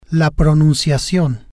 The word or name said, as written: la pronunciación